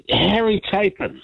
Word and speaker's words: Harry Chapin: Anyway, Harry Chapin. – Yes. Have I pronounced that right? – Yep.